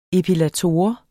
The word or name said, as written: epilator